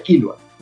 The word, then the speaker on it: Killua: Se pronuncia Killua.